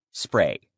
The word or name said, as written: spray